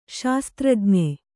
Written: ಶಸ್ತ್ರಜ್ಞ

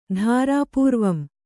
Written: ಧಾರಾಪೂರ್ವಂ

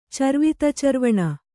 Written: ಚರ್ವಿತಚರ್ವಣ

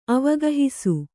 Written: ಅವಗಾಹಿಸು

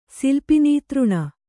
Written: ಸಿಲ್ಪಿನೀತೃಣ

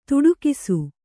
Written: ತೊಡಕಿಸು

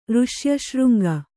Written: ಋಷ್ಯಶೃಂಗ

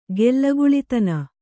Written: ಗೆಲ್ಲಗುಳಿತನ

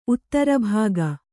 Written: ಉತ್ತರಭಾಗ

